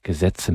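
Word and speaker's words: Gesätze: Jedes dieser einzelnen Gesetze, wie das auch heißt, Gesetze mit Ä in der zweiten Silbe.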